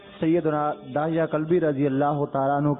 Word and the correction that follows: دحیہ: حضرت سیدنا دحیہ قلبی رضی اللہ تعالیٰ عنہ، صحیح تلفظ یہ دحیہ دال پر زیر دحیہ بھی ہے، صحیح تر یہ کہ دحیہ.